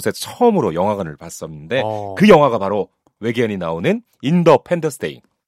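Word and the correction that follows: Independence Day: 인디펜던스 데이.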